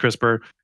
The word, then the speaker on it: CRISPR: It's an acronym, pronounced CRISPR. It stands for Clustered Regularly Interspaced Short Palindromic Repeats.